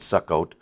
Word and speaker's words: Succoth: Interesting, because the 17th verse says, and Jacob journeyed to Succoth, or Succoth, the way it looks like it's pronounced in our King James, but it's Succoth, and built him a house and made booths for his cattle, pens. Therefore, the name of the place is called Succoth. That's the proper pronunciation, which means booths, by the way.